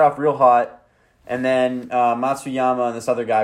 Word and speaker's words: Matsuyama: Matsuyama.